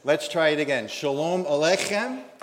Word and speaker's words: Aleichem: In order to say Aleichem properly, the accent is on the K in Alech. Just pretend you have a bagel stuck in your throat. Try one more time. Shalom Aleichem.